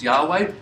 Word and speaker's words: Yahweh: We assume that the right pronunciation is Yahweh.